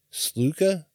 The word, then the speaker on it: Sluka: Oh, I know I'm pronouncing that wrong. S-L-U-K-A. Sluka? Sluka. Uh-huh. Yep.